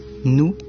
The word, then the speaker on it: noue: Là, nous, ça s'écrit nous, N-O-U-E.